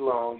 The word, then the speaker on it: Long: Long, using my real name, Jerry B as in basketball, LONG.